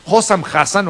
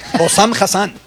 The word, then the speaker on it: Hossam Hassan: Vamos a escuchar al entrenador egipcio, Hossam Hassan o algo así. – Hossam Hassan. – Hossam Hassan, disculpe.